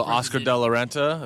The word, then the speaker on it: Oscar de la Renta: Oscar De La Renta. It was a classic, timeless fit. She looked amazing. – I'm just take your word for it. That's how you pronounce that. – Yeah, it is.